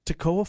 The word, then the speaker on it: Toccoa: No M in it. Tocoa Falls. T-O-C-C-O-A. Pronounce it for me. You've been in radio for a long time. T-O-C-C-O-A. – Tocoa Falls. – Tocoa Falls.